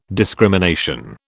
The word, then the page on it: discrimination: British English